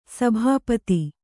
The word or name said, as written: ಸಭಾಪತಿ